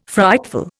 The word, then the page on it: frightful: Indian